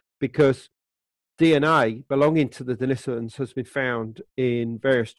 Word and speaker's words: Denisovans: And then in 2010, the Denisovans, that's the correct pronunciation,